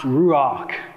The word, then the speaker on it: ruach: There's this Hebrew word. It's pronounced ruach.